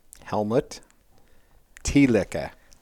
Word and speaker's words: Helmut Thielicke: Helmut Thielicke.